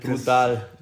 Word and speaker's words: Brudaddal: Den schreibt man B-R-U-D-A-D-D-A-L. Brodal.